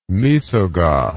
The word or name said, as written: Moesogoth